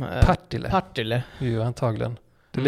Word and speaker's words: Partille: Ja, eh, Partille.